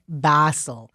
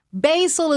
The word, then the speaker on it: Basel: And it is so important for people to pronounce it correctly, because there is no better way to prove your ignorance and uncouthness than saying Art Basel. Basil is what you make pesto out of.